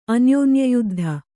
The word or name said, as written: ಅನ್ಯೋನ್ಯಯುದ್ಧ